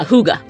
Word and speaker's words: hygge: Woman, you want a hygge. – What the heck? – Hygge. So hygge, people may have heard of this and not even known because it's pronounced differently than the way it's spelled.